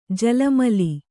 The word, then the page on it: ಜಲಮಲಿ: jala ali